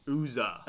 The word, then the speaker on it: Uzzah: Okay, it's spelled U-Z-Z-A-H. – Okay, all right, maybe I was wrong. – Uzzah. – Oh, here it is. 1 Chronicles 13. Uzzah. Yep. – 1 Chronicles 15. It talks about Uzzah as well.